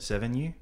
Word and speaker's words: Sevigny: Sevigny.